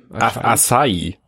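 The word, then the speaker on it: açaí: Aisha. Aishai. – Acai. – Acai. Aisha-Geschmack. Ich dachte, hä, was ist das denn? Ayran-Geschmack oder Aiwa-Geschmack vielleicht. Ich dachte, das, das, das C mit dem Nupsi drunter spricht man äh, SCH aus. Aber das I mit dem, mit dem Akzent spricht man, glaube ich, separat. Acai. – Vielleicht ist es Acai, Asai, irgendwie so.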